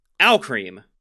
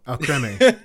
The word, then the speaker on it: Alcremie: But number nine, we have, and I'm convinced I'm still saying this right. Alcream. – No, Alcremie. – It's Alcream! It's just cream! – It's Alcremie.